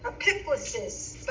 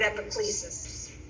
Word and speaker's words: epiclesis: Some scholars I know pronounce it epiclesis, but I have always heard the word epiclesis. But there's Ed Foley, who I introduced you to a couple of weeks ago. He says epiclesis, but I have always heard epiclesis from all of the scholars I learned from.